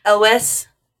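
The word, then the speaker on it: Elwes: Yeah, I was going to say, how do you pronounce Yules, his name? – Ellis. Carrie Ells.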